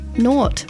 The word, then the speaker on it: naught: N-A-U-G-H-T. – That's not pronouncing, that's spelling. – Naught. – Naught. – Yeah, that's how I would say it. – Like nautical.